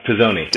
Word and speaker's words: Pisoni: I am very excited to be speaking with Adam Pisoni, who, I hope I said that right. I didn't even ask you. – Close enough. Pisoni. – Did I? Pisoni. Excuse me.